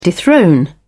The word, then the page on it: dethrone: British English